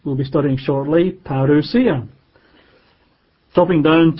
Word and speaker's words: parousia: And the word here, coming, is again the Greek word parousia. We would spell that in English as a P for Peter, P-A-R-O-U-S-I-A, parousia, P-A-R-O-U-S-I-A.